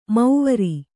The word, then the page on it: ಮೌವರಿ: mauvari